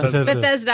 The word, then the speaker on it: Bethesda: Bethesda. I'm sorry. I always say it wrong.